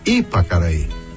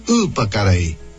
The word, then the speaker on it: Ipacaraí: Porque ele não disse Ipacaraí. Ele puxa por ser Y, por ser Y, ele puxa para aquela pronúncia do Guarani.